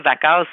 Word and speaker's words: Wachau: C'est comme marqué Wachau, OK? Mais on prononce ça Wachau si mon genre d'Autrichien allemand est bon.